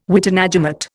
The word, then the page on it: witenagemot: Indian